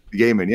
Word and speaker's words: Gaiman: Gaiman, yeah.